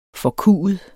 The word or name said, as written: forkuet